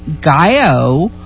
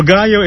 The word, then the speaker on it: Gallo: Well, Gallo is an acronym for our organization's name, which is Gay and Lesbian Latin Organization, G-A-L-L-O. And, of course, the double L's are pronounced as a Y.